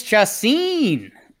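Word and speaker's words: Chacín: Moreland, Vasquez, and JBJ with, how do you pronounce his pitcher's name? Julius Chacin? – Jules Chacin.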